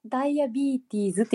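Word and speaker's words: diabetes: ねなんか、英語では本当はダイアビーティーズっていう発音なんでしたっけそうですね。その方が近いと思いますね。ダイアビーティーズっていう発音が比較的近いと思いますけどね。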